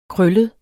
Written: krøllet

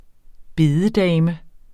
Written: bededame

